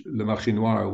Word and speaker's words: Le Marché Noir: And your pronunciation of the French Le Marché Noir was um, spot on, immaculate.